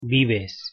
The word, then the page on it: vives: Spanish